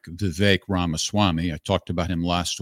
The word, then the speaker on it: Vivek Ramaswamy: It's Vivek Ramaswamy, rhymes with bake, Vivek Ramaswamy.